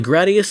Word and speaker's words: Gradius: Gradius, which, yes, officially seems to be pronounced like grad instead of grade, or grod for those people,